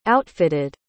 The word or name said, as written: outfitted